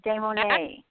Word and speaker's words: Demonet: Is it Kara? – It's Kara Demonet. – Demonet. That's so much more beautiful than what I was saying.